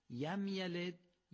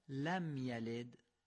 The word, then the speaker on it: لم یلد: بنابراین یم یلد یا لم یلد دومی درسته.